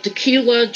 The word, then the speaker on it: Dacula: I'm originally from a town called Decula, Georgia. It's spelled D-A-C-U-L-A. Decula.